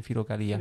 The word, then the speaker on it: Filocalia: Filocalia.